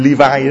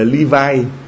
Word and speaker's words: Levi: Levi đó, hiệu hàng hiệu đó, đọc tiếng Anh gọi là Levi, còn tiếng mình Việt, tiếng Việt mình đọc là Levi, L-E-V-I.